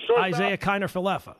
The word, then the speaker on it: Isaiah Kiner-Falefa: Isaiah Kiner-Falefa, IKF.